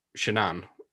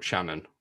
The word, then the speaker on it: Shanann: Now, apart from the woman who has been murdered being called Shanann and not Shannon, because that's how it's spelled, which is a problem.